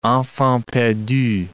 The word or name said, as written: enfants perdus